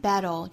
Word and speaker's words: battle: It's a constant battle. This constant, the first 元音上下开口都更大。更开 constant. It's a constant battle. 这个 battle 呢，刚才讲到英式发音是舌边爆破，是爆。在美式发音里呢是 flap t 弹舌音再加舌侧音。